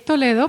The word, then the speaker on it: Toledo: So it's Charo Toledo or Toledo? – Fine. It's Toledo, pero it's fine.